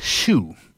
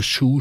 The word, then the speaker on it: Chew: Hans Schuh. Entschuldigen Sie da meine Aussprache. Schuh schreibt sich hier C-H-E-W.